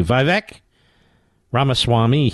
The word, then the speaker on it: Vivek Ramaswamy: Vivek Ramaswamy, he was on Fox News today.